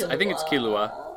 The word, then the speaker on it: Killua: No, I think it's Killua. Yeah, I think it's Killua. I was saying it wrong.